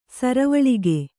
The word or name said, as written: ಸರವಳಿಗೆ